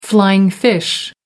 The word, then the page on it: flying fish: American English